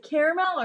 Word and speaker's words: caramel: Have you seen the way it's spelled? It's C-A-R-A-M-E-L. – M-E-L, yeah. – Caramel. It's not caramel. It's like they forget the A is there.